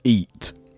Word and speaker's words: egat: Eat. That's spelled E-A-T. – E-G-A-T. – E-G-A-T. E-G-A-T. – The G is silent.